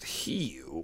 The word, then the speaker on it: Hugh: It wasn't Hubert or Huey, it was Hugh. – Yeah. His mother was from Swedish descent, his father was English and German. – You're supposed to pronounce it Hugh.